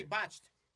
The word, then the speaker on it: botched: Batch, botch, ¿es que se pronuncia eso? Sí, botched. Sí, como un eh, eh, implante de eso de nalga que salió mal.